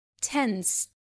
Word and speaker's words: tensed: في الحالة دي سواء أضفنا إي دي أو دي، فأنا مش بنطق الإي خالص وبنطق الدي على إنها تي، فبالتالي tense tensed tense tensed.